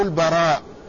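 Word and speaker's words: البرّاء: فهناك من الرواة من اسمه البراء بتشديد الراء، ومنهم من اسمه البراء بتخفيف الراء.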